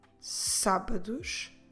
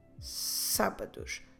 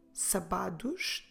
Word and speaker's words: sábados: Make sure you pronounce sábados, emphasizing here the first syllable, sa, sábados. Don't say sabados, that's not correct. You should stress the first syllable, sábados. Vamos repetir a frase.